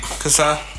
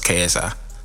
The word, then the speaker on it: KSI: How you think you say that shit? – Si. Kasai. – No, it's KSI. But anyway, literally KSI.